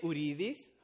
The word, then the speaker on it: Uridis: So there's some people who still call me Daniel. That's fine. If you cannot pronounce my name, that's totally fine. I have like 1,000 people who call me Daniel. That's totally fine. But if you can pronounce my name, that's even better. So if you can say Uridis, how you pronounce my name is cool.